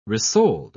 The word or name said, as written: resold